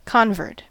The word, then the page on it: convert: American English